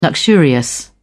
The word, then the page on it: luxurious: British English